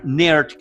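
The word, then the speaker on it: Naird: Y eso lo logramos ver a través eh, del personaje del general Mark Nerd, que es otro juego de palabras. Nairt se escribe, pero es como si fuera nerd, ¿no?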